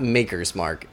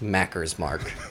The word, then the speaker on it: Maker's Mark: I said, it's not Maker's Mark. It's pronounced Macker's Mark. I said, if you go to Kentucky and you call it Maker's Mark, everyone's going to think you're a loser. Like, they're just going to think you have no clue.